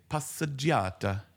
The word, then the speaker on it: passeggiata: Passaggiata. – Mm. – Probably saying it wrong. Let's have this. – Passeggiata. – Passeggiata.